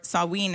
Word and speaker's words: Samhain: So Halloween originates in the Druid pagan holiday of Samhain, or Samhain is how they pronounced it.